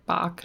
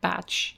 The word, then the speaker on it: Bach: His name is spelled the same way and pronounced like Bach, not Batch.